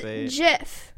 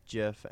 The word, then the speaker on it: GIF: It's pronounced JIF. J-I-F. – Let me see. Let's see. – It's on BBC News. – The Oxford English Dictionary accepts both pronunciations. He said they are wrong. It's a soft G pronounced. GIF, end of story.